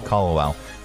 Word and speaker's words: Kalawao: Kal- Kalawao.